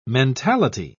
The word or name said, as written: mentality